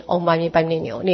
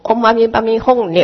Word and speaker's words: 唵嘛呢叭咪吽: 他就这样心动一个念，就是那个豆子会跳过来跳过来跳到今天的功课的那个数量跳完了，就是今天唵嘛呢叭咪牛念完了。但是有一个人说，哪能这样子，你要念唵嘛呢叭咪吽，那个发音是这样，结果他念了唵嘛呢叭咪吽之后，那个跳子都不动，哎，而且起烦恼，每天在念的时候唵嘛呢叭咪牛吽，唵嘛呢叭咪吽牛，就自己在那边起烦恼。